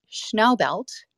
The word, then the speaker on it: Schnaubelt: So it's Schnaubelt. It's like Schnauzer.